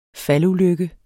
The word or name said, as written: faldulykke